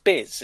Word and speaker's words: Bis: But there's a band called, I think it's pronounced Biz, it's just BIS.